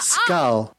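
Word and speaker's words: skål: Skull. – Hello? – It's pronounced... It's S-K-A-L. Skull.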